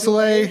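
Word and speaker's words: Soulé: Michael Soule? Is that it? – Yes, yeah, Michael Soule,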